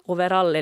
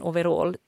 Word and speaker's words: overall: Jag uppfattar det som att, att overall är lite sån här äldre personers uttal medan overall är vad personer i min ålder och yngre säger.